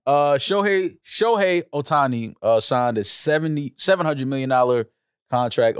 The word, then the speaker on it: Shohei Ohtani: Shohei Ohtani? – Yeah.